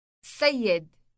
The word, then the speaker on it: سيد: سيد. سيد. – Note the elongated S sound on the beginning of the word.